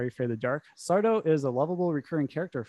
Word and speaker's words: Sardo: Sardo. – No, yeah. Has no mister accent on the toe. What's say it the way it's spelt.